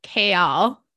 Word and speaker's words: Chaol: If you haven't read this or you've only like listened to the audio, it's spelled like chaos, but with an L at the end. C-H-A-O-L. And it's supposed to be pronounced like Kale, and I just like can't do it. So it's just Kale to me, like the veggie.